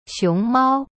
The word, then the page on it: 熊猫: xiong mao